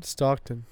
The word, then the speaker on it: Stockton: Is that how you say his name? Stockholm? – Stockton. – Stockton. – You're thinking of the city, Stockholm.